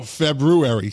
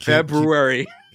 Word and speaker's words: February: No, February. Say it right. – February. – Yeah. There you go.